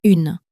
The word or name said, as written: une